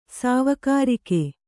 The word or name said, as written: ಸವಿಗಾರಿಕೆ